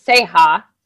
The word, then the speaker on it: Seha: Well, okay, so my last name is Seha, right? Uh-huh. So I call it the Seha, but spell it Say-Ha.